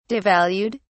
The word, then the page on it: devalued: British English